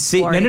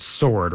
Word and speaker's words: sword: But he'll be like, you know, it's Sword, right? Like without the W.